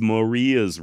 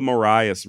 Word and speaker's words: Marias: So in all actuality, if you want to be correct, it's Maria's River, not the Marias River. However, it does appear the Montana locals do call it the Marias, so I also chose to go with that pronunciation.